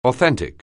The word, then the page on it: authentic: anglais